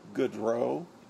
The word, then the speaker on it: Goodreau: James Joseph Patrick Goodrow. The last name is spelled G-O-O-D-R-E-A-U.